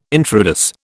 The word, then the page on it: intrados: British English